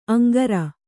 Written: ಅಂಗರ

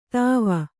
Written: ದುವ್ವೆ

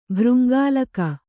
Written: ಭೃಂಗಾಳಕ

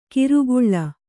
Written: ಕಿರುಗುಳ್ಳ

